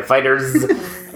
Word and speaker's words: FighterZ: The other one I played there that I was looking forward to was the Dragon Ball FighterZ, which I think it's pronounced like that, even though it's a Z for fight. Yeah, FighterZ.